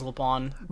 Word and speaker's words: LeBlanc: Is the C silent? – They don't pronounce the C in the English VO, which is what I listen to. So I don't know. That's just how I've always said it is LeBlanc.